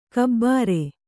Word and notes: ಘಾಬರಿ: Kannada

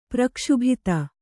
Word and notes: ಪ್ರಕ್ಷುಭಿತ: prakṣubhita

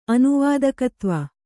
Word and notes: ಅನುವಾದಕತ್ವ: anuvādakatva

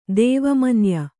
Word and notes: ದೇವಮನ್ಯ: dēva manya

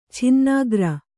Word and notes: ಚಿನ್ನಕಾರ: Kannada

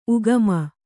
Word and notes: ಉಗಮ: Kannada

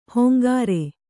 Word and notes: ಹೊಂಗಾರೆ: hongāre

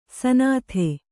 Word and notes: ಸ್ನಾತೆ: snāte

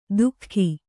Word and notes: ದುಃಖಿ: kannada

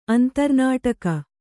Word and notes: ಅಂತರ್ನಾಟಕ: Kannada

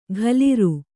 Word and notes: ಘಲಿರು: ghaliru

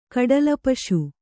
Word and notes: ಕಡಲಪಶು: kaḍala paśu

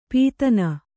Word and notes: ಪದಿನ್: padin

